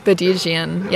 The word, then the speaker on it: Badigian: But like the crucial thing is like the fourth syllable. – Yeah. – Because like I think a lot of people, like it's like B-A-D-I-G, then there's an I. – Yeah. – B-A-D-I-G-I-A-N.